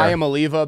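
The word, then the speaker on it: Iamaleava: I am Oliva because it rhymes with I'm a believer. – Donnie, do you know the correct pronunciation here? – I thought it was I am Liava.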